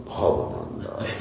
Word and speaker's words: Bhavananda: Bhavananda. I've used my Bengali accent. Bhavananda Roy. Right? That's how you pronounce it. Instead of Sanskrit, I have to speak in Bengali here.